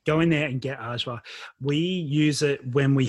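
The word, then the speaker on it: ajvar: Ajwa is the best.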